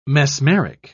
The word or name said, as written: mesmeric